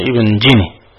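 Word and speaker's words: جني: ومن هذا قالوا في العالم الإمام النحوي المعروف الذي هو ابن جيني أن أصل اسمه أعجمي من هذا الباب، الحرف الذي بين الجيم والكاف أي ابن جيني، لكن لما كانت العرب لا تنطق بهذا الحرف جعلوه جيما خالصة أي ابن جيني.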